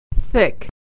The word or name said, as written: thick